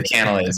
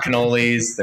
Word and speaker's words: cannelé: It took me forever to learn how to pronounce that, but they're cannelés. They're not cannolis. They're not cannels.